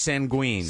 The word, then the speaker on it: sanguine: And it's pronounced sanguine. – Sanguine. – I have sanguine. Which sanguine. – S-A, big N, big G, G-W-I-N is the phonetic spelling.